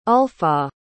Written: ulfah

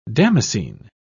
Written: damascene

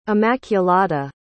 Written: immaculada

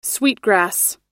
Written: sweetgrass

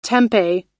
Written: tempeh

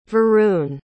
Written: varun

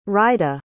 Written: rida

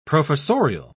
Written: professorial

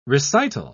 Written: recital

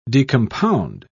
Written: decompound

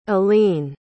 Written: elleen

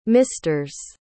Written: misters

